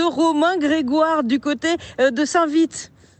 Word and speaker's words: Saint-Vite: Et on est avec une habitante de Saint-Vite, je me suis renseigné sur la prononciation, on dit bien Saint-Vite, comme l'allure du peloton.